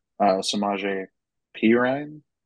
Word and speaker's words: Samaje Perine: I know he's being worked into this offense, and he's kind of split in time with Samaje Perine.